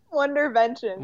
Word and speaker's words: Wonderventions: Am I even saying that right? Wonder inventions. What are these? – Wonderventions. – Wonderventions.